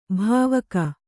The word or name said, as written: ಭಾವುಕ